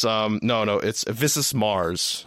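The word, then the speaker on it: Visas Marr: It's Visus Mars. I think that's how you pronounce it. That's right.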